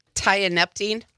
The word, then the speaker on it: tianeptine: Tianeptine.